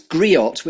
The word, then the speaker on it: griot: Which I think is, it's griot, which is a new word that I've learnt by researching you. Have I pronounced that correctly? – Griot. Yeah. The T is silent.